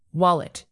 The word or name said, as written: wallet